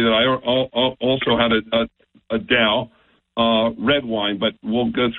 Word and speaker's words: Daou: One of them is called Dow, like D-O-W, but it's spelled D-A-O-U.